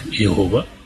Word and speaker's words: Иегова: часто в русской литературе принято произносить это имя как Иегова.